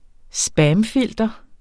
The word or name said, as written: spamfilter